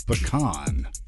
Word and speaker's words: pecan: I said that because usually I say pecan and people are like, yo, you're wrong. – There's no wrong answer. – You don't think there is? – No. No. – Okay. – The correct pronunciation, I believe, is pecan.